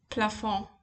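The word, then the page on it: plafond: Bavarian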